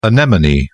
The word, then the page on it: anemone: British English